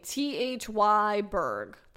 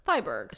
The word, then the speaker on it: Thyberg: It's a Swedish name, Thyberg. Thyberg.